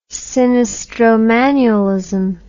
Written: sinistromanualism

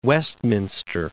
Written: Westminster

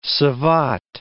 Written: savate

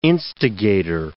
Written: instigator

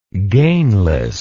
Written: gainlessness

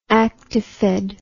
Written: actifed

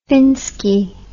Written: finski

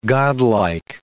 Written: godlike

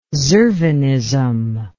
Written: zervanism

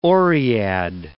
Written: oread

